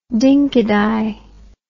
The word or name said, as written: dinky di